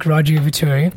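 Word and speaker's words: Raju Ivaturi: Follow me on Instagram, raju.i, and follow Facebook, Raju Vaturi. My name is spelled R-A-J-U, space I-V-A-T-U-R-I.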